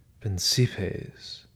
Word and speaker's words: principes: We, men of another age, we suppose that without principes, Pavel Petrovich pronounced the word softly, the French way, while Arkady, by contrast, pronounced it principles, with the accent falling hard on the first syllable.